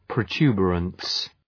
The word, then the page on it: protuberance: prəʋ’tju:beərəns